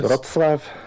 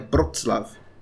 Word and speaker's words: Wrocław: E daí Breslau, que é Proslav, o Proslav Proclau, Eu não sei como dizer mais a pronúncia em, em polonês. Wrocław. Né, a, tu, tu fala com B, né? Wrocław.